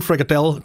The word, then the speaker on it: Frikadelle: det er ikke Frikadelle. – Til sidste E skal udtales, nu kommer nu ind i kampen, mand.